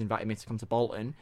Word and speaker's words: Bolton: Anyone in Bolton pronounces Bolton without the T and it's Bolton.